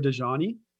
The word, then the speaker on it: Dajani: Yeah, so my name is Munther Dajani with Elumin Agency.